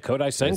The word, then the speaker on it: Kodai Senga: Kodai Senga? – Yeah, Kodai Senga.